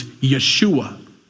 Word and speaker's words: Yeshua: But his real name, if it was in Hebrew, would be Joshua, or they would pronounce it, because they don't use the J sound in Hebrew. It's Yeshua, which is a combination of two words.